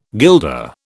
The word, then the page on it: guilder: British English